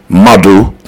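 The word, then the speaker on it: Madhu: And then you're going to go on YouTube and type in Madu. That's my artist's name. And then music, but spell it with a Q, M-A-D-H-U, then space M-U-S-I-Q, Mother Music, and then you'll see my channel.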